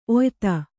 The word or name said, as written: ಒಯ್ತ